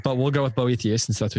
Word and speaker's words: Boethius: Boethius book or Boethius book, depending on how you want to pronounce it. How do you pronounce it? – I would say Boethius.